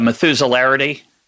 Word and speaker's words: Methuselarity: Let me see if I can pronounce it correctly. The Methuselarity. – Yes.